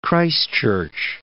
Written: Christchurch

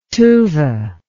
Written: Tuva

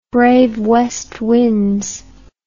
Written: brave west winds

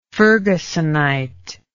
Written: fergusonite

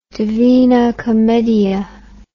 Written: Divina Commedia

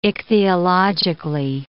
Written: ichthyologically

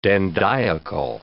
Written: dandiacal

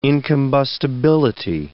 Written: incombustibility

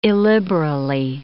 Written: illiberally

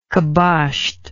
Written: caboshed

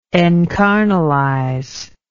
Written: encarnalize

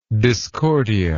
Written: discordia

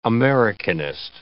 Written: Americanist

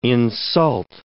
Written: insult

